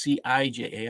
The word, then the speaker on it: CIJA: It's CIJA. I apologize for saying that wrong.